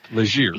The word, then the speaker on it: Legere: So apparently last year, what, T-Mobile, John Legere, is that how it's pronounced? – John Legere. – Legere.